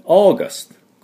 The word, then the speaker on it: August: では、発音ですが、この、 au の部分で、 August。これは自動ドアのこと、o, out, o じゃないですか。o って伸ばすだけです。だから、喉の奥で、あーで、 August。